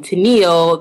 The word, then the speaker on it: Tennile: I always get, well, we're probably around the same age, but usually people, when they hear my name, Tennile, they automatically think of the group back in the days, Captain and Tennile.